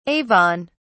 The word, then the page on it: Avon: British English